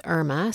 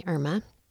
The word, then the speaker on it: Erma: Her name is Irma, spelled with E-R-M-A, Irma.